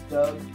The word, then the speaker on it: Doug: My name's Doug. – Listen to us on SoundCloud. – Rhymes with Bug.